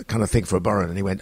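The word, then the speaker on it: bodhrán: That's a fiddle, you know, and a burren, which is an Irish. – What's it called? – A burren. I think it's spelled Bodran, Bodran, but a bit of burren.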